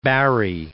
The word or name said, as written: Barrie